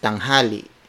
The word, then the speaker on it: tanghali: which is tanghali.